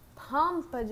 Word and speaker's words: Pompe: Pompa disease.